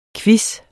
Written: quiz

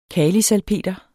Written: kalisalpeter

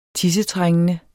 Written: tissetrængende

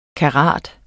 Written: karat

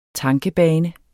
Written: tankebane

